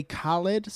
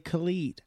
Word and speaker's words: Khaled: It's some people say Khaled, some people say Khalid, and he's fine with them both because he talked about it in a song, being fine with both.